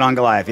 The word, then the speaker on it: Ankalaev: In, is that how you say his name? Ankalaev? – Close? Magomed Ankalaev, yes.